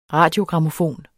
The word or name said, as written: RADIOGRAMMOFON